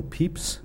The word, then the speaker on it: Pepys: One way to date this birth lies in the career of Samuel Pepys, as I've learned to pronounce him. It's odd British pronunciation.